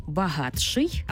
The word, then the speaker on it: багатший: «Ти» і «ши» утворюється «чи-ши». Пишемо «багатший», але вимовляємо «багатший».